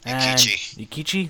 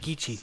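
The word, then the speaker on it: Eikichi: It revolves around a 22-year-old guy named Inichi Onizuka. – Ikichi. – That's how you say it. Ikichi.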